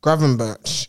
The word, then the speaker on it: Gravenberch: Gravenberch.